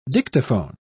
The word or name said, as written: dictaphone